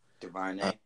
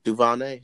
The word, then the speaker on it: DuVernay: DuVernay. – DuVernay, here we go.